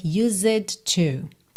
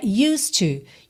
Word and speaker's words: used to: Primeira coisa para a gente prestar atenção aqui é a pronúncia, para não dizer used to. Tá? Esse é mudo. E o D já liga no T. Então o som é used to.